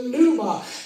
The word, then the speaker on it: pneuma: It kind of spelled like pneumonia, because P-N-E-U-M-A.